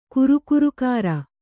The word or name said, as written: ಕುರುಕುರುಕಾರ